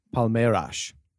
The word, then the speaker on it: Palmeiras: Palmeiras. – Nice. By help, I did mean just do it for me. Yeah. So beat Palmeiras 2-1 in the Club World Cup, adding another trophy to the jam-packed trophy room of Chelsea.